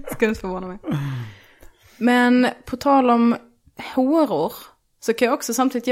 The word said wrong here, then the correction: horor: Ja, det var ett intressant uttal av horor. – Horor? – Det var väldigt mycket å. Alltså så, A med en ring över. – Håror.